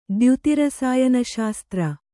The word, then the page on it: ದ್ಯುತಿರಸಾಯನಶಾಸ್ತ್ರ: dyuti rasāyana śastra